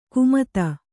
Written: ಕುಮತ

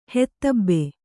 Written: ಹೆತ್ತಬ್ಬೆ

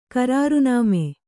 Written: ಕರಾರುನಾಮೆ

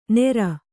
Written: ನೇರ

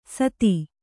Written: ಸಾದಿ

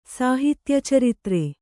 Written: ಸಾಹಿತ್ಯಚರಿತ್ರೆ

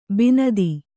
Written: ಬಿಣ್ಣಿತು

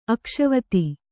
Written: ಅಕ್ಷವತಿ

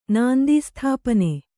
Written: ನಾಂದೀಸ್ಥಾಪನೆ

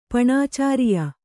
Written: ಪಣಾಚಾರಿಯ

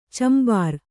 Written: ಚಂಬಾರ

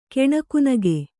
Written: ಕೆಣಕುನಗೆ

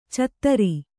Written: ಚತ್ತರಿ

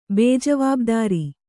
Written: ಬೇಜವಾಬ್ದಾರಿ

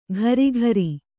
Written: ಘರಿಘರಿ

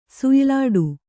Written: ಸುಯ್ಲಾಡು

